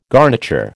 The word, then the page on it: garniture: American English